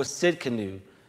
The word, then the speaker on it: Tsidkenu: Jehovah Tsidkenu, right? The T is silent, right? Jehovah Tsidkenu, God, our righteousness.